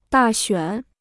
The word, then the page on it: 大选: dà xuǎn